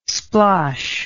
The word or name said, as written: splosh